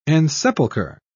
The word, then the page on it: ensepulcher: British English